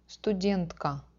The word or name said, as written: Studentka